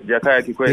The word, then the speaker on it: Jakaya Kikwete: Jakaya Kikwete. – Jakaya Kikwete.